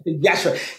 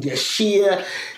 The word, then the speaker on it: Yashere: Yashere, because the I is almost, it's Yashere, the I is silent. So I dropped the I to help people pronounce it properly. And they still mispronounce it for a long time. I get called Yashir, Yashu, like, oh, so I have to make, I've got this thing now where I go, it's Yashere, think Yash, hooray.